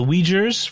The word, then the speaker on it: Uyghur: Who are OUI. OUI. – UIGHUR. – UIGHUR. – Uyghur. – Yeah. I do not pronounce that properly because once I sort of visualized the word, I could see it, but it does. I do not do the UI as we.